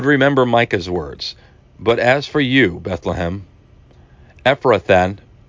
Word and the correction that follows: Ephrathah: I hope I'm saying this right, Ephrathan, it's Ephrathah, got to check my spelling on that,